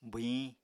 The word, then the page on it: bwhiñ: apatani